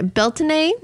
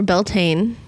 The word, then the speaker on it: Beltane: Okay, Beltane or Beltane, however you want to pronounce it, does mean, like I said, in Celtic, it means bright fire.